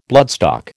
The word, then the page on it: bloodstock: American English